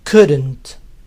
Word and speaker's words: couldn't: Este could not también se puede acortar y se pronuncia couldn't. Couldn't. Como siempre, la T final muchas veces no se oye si al couldn't le sigue otra palabra.